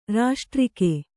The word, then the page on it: ರಾಷ್ಟ್ರಿಕೆ: raṣṭrike